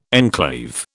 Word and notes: enclave: British English

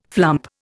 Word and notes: flump: Indian

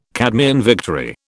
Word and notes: Cadmean victory: British English

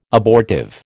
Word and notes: abortive: American English

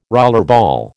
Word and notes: rollerball: American English

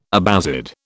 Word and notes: Abbasid: British English

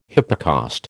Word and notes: hypocaust: American English

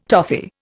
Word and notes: toffee: Indian

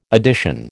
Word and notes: addition: American English

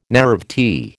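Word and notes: naïvety: American English

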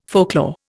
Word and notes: folklore: Indian

